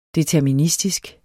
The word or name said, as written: deterministisk